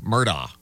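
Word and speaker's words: Murdaugh: And he does pronounce his name Alex Murdoch.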